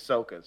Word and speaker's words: Sokka: By the way, I just looked it up and there are numerous definitive sources that say it's the correct pronunciation of Soka.